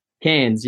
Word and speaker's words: Cairns: You are originally from, how do I pronounce it? Cairns? Cairns? – Cairns, yeah.